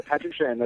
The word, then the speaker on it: Patrick Shand: Yeah, Patrick Shand.